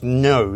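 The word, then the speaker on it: Ngo: But he basically tweeted out on the 7th of March that he had read the book written by Andy Ngoe, who's, I think, pronounced No, isn't it?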